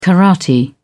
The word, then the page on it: karate: British English